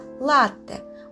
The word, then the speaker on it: латте: Обратите внимание, как я произношу название этого вкусного кофейного напитка – латте. Ударение на первый слог. Звук «т» произносится долго и, и чувствуется, что это удвоенный «т». И именно так и нужно писать. На конце пишем букву «е», а произносим – звук т твердо, латте.